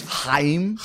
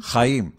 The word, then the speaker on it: Chaim: Well, let me pronounce it with you. It's Chaim. – Chaim. – That's the... – Except you're doing more. It's more... Like, it's like a cat throwing up a hairball.